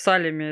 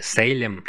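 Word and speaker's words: Salem: Сказал, вы представляете, что у нас там творится в Салеме? Это вообще Ахтунг. – Сейчас город Салем или Сейлем, как его, наверное, правильнее произносить, как я уже сказал в начале, пригород Бостона с населением всего 43 тысячи человек.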